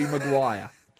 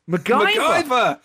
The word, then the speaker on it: Maguire: This is new Chelsea boss Thomas Tuchel mispronouncing Harry Maguire. – MacGyver.